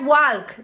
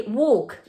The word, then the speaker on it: walk: No digas walk, di walk.